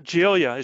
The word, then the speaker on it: Giglia: Did I say that right? Giglia. – Nope. Giglia.